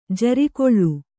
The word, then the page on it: ಜಾರುಗಲ್ಲು: jārugallu